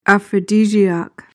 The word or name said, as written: aphrodisiac